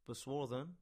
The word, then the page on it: Boswarthen: English